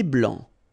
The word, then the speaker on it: blanc: Un thé blanc, final C not pronounced.